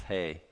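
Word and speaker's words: hey: It's not he, it's hay. It's pronounced hay. It has the H sound as in hay.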